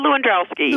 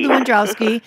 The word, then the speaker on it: Lewandowski: Yeah, Lewandowski. – Lewandowski, who is a working dairy farmer and an attorney who addresses environmental issues and other agricultural justice problems in upstate New York.